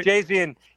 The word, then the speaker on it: Gavargazian: Jill Gavargazian. I always have a hard time saying that. I believe I said it right. – Gavargazian, yes, we love you.